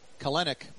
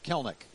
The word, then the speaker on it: Kelenic: It looks like Kelenic, but it's pronounced Kelnick. K-E-L-E-N-I-C from Waukesha, Wisconsin.